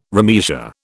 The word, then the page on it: remeasure: British English